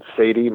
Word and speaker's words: Saady: It's Brian Saady.